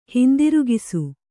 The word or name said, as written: ಹಿಂದಿರುಗಿಸು